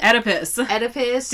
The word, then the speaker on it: Oedipus: Was his, like, Oedipus and shit? – Oedipus. Damn. Learn to read. – It starts with an O. – The O is silent. – O-E. Shit. Oedipus.